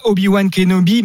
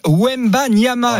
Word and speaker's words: Wembanyama: Ce n'est pas Obi-Wan Kenobi, mais Wemba Nyama.